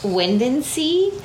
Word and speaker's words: Windansea: This dog's name is Wind and Sea, like wind and sea, but it's spelled W-I-N-D-A-N-S-E-A.